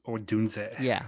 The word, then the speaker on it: Odunze: Romo Odunze.